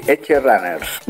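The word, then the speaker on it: Edgerunners: Como nos acercamos y bueno, a Cyberpunk and Edge Runets. ¿Lo he dicho bien? No lo creo. – Ah, ah. Eh, sí. Eh, eh, Cyberpunk, perdón, o Edge Runners, más o menos. Lo has dicho tú mejor, lo has dicho tú mejor.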